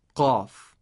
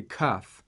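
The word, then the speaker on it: qaf (قاف): And the right sound is like this, qaf, qaf, qala. Okay, English speakers might pronounce qaf like a kaf, so you should also try to practice pronouncing the right way of pronouncing the letter qaf.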